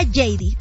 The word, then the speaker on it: Heidi: Se escribe Heidi, se pronuncia JD.